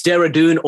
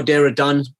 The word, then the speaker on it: Dehradun: Yeah, it's Dehradun or Dehradun. Either is acceptable.